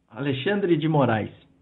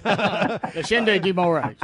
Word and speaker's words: Alexandre de Moraes: Alexandre de Moraes. – All right. Say that, just like he said it. – Alexandre de Moraes.